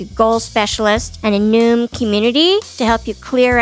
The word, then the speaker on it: Noom: Noom rhymes with boom. – Boom. – N-O-O-M.